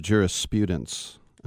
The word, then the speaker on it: jurisprudence: Some interesting stories in the world of jurisprudence. And that's right, with the long U. I know someone who always gets mad and says, it's jurisprudence.